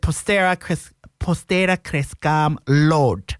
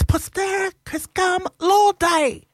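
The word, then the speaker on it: Postera Crescam Laude: and it said Postera Crescam Lord. And then I got to school, and Mr. Grugan said to me, "It's Postera Crescam Lord Day."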